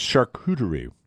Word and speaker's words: charcuterie: And that's charcuterie. – Correct. – Is that how you pronounce it? – Oh, look at you.